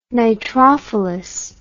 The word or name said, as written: nitrophilous